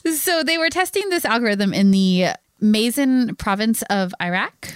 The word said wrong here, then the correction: Maysan: Oh, Paul's listening to this going, that is not how you pronounce that. – No, it's not how you pronounce it. I'm so sorry, Paul. – Probably Mazan. – Mazan? Okay. Yeah. Maysan.